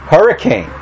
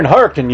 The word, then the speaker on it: Hurricane: The name of the town is spelt H-U-R-R-I-C-A-N-E. What's that spell? – Hurricane. – What's it spell? – Hurricane, right? You know how they pronounce it? Hurricane. You're in Hurricane, Utah, not Hurricane, Utah.